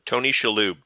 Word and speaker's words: Shalhoub: Shalhoub? How do you say his name? – Tony Shalhoub. – Shalhoub. Monk himself.